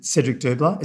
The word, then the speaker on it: Cedric Dubler: It did, and you know, is it Cedric Dubler? Is that how you pronounce his name? – Yes.